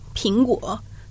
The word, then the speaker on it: 苹果: Ping in the word apple, 苹果 sounds like the word peace in Mandarin, which is used in the Chinese for Chinese for Christmas Eve and the carol Silent Night, 平安夜, which means peaceful evening.